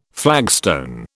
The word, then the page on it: flagstone: British English